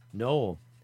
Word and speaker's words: Gnoll: Gnoll.